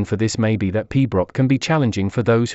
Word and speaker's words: pibroch: Piabarichad, pronounced pibroch, is a classical music genre for the Great Highland Bagpipe that involves playing complex and melodic tunes with a slow tempo.